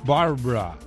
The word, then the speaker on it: Barbara: Una segunda tormenta, Barbara. Hay que pronunciarlo así, ¿no, Lázaro? – Sí, te quedó muy bien.